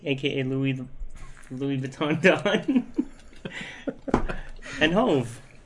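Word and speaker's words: Hov: How was Yeezy and Hawes? Am I saying that right? – Yeezy and Hove.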